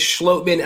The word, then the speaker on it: Schloman: And we have confirmation how to pronounce his last name from the man himself. After, like I told him, I've been mispronouncing it for months now. It is Schloman, and I'm so happy and excited to talk to him.